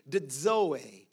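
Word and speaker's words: dzoe (ζωή): Very simply, I'm come to give you full life, because the word life here in the Greek is the word zoe. It's spelled Z-O-E, but it's pronounced with a D, dzoe.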